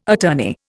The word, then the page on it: attorney: Indian